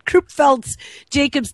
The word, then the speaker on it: Creutzfeldt-Jakob: called Krupchev's, which I never say it right, Creutzfeldt-Jakob's disease, or CJD for short.